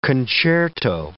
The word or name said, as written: concerto